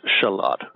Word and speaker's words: shallot: that it's pronounced shalot.